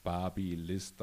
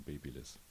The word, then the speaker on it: Babyliss: ist natürlich Barbie-List dann äh, etwas besser verträglich als äh, die doch sehr stark nach Baby konnotierte Aussprache von Baby Babyliss.